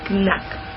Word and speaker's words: Knack: A eso le siguieron otro, otro tráiler de Killzone, Shadowfall y Drive Club, Acompañado de Infamous, Second Son y Knack. No sé cómo se dice eso. – Lo has dicho bien, tranquilo.